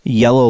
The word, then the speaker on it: Iello: Yellow like the color? – I-E-L-L-O. – Interesting. – Which I believe is pronounced yellow.